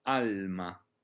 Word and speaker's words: alma: Ecco, sarebbe un errore inghiottire la L, all'ama, ama, alma.